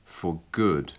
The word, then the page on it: for good: English